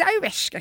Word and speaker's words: ayahuasca: Inte ayahuasca kanske, eller ayahuasca, jag kan inte uttala det.